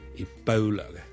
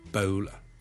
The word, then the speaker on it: Ebola: Ladies and gentlemen, boys and girls, hi, 跟最近咧，如果大家有睇國際新聞咧，都應該會留意到 Ebola 呢個伊波拉病毒咧，喺某啲地方肆虐嘅。 Ebola 伊波拉病毒咧，我哋雖然廣東話我哋譯做伊波拉波啦，我哋嘅 O 呢個發音，但如果英文咧，我哋通常咧係讀 Ebola 嘅 Ebola bow bow，好似弓箭嘅弓嗰個 bow。 OK，咁所以咧唔係。